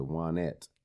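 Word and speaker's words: Waynette: And he named her, it's spelled Wayne, but he calls her Juanette. – Yeah. – It's W-A-Y-N-E-T-T-E.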